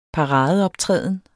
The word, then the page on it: paradeoptræden: dansk